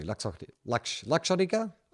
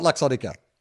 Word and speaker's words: Luxottica: Oh, like luxury. Luxottica? Would it be Luxottica or Luxottica? Luxury. L-U-X. Luxottica. Let's call it Luxottica.